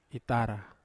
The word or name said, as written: tara